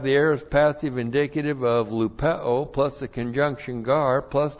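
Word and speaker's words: lupeo (λυπέω): L-U-P-E long O, lupeo.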